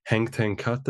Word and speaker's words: Henk ten Cate: Afterwards, so then you got an opportunity then to become the assistant manager or assistant coach under Henk ten Cate. Have I pronounced that correctly? – Yes, exactly. Yes.